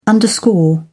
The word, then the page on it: underscore: British English